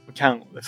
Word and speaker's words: キャンオ: これ出来上がったミッションの世界に、カンオって読むんですかキャンオって呼んでるんですかキャンオですね。